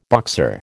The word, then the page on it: Boxer: American English